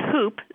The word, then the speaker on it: hoop: Yeah, they're related for sure, because some people do pronounce the H in hoop, like whooping cough, and some people don't, and so they're actually the same. They're the same expression.